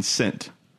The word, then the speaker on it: scent: Scent, for those of you who don't know, is spelled S-C-E-N-T. If the S was silent, it would be sent, C-E-N-T, like money. But if the C was silent, it'd be S-E-N-T, as in like the past tense of send.